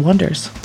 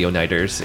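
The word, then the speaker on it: Oneders: That's the joke that everybody calls them, the Oneiders, in the movie. – Why? – Because it's spelled O-N-E. It looks like Oneiders. And they're like, no, it's the Wonders.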